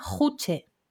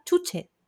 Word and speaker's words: Juche: No se llama Juche, no es la ideología juche, tío, es la ideología chuche, las chuches. – Esa es la ideología. – Es la ideología chuche, se pronuncia así, entonces, por favor.